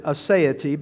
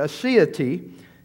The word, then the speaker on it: aseity: Some may pronounce it aseity, but aseity is the next one that is in the list.